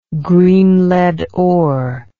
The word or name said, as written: green lead ore